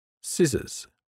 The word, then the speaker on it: scissors: The silent C. The C isn't pronounced when it appears with the letter S to form SC. Scissors, fascinating, scenario, muscle, miscellaneous.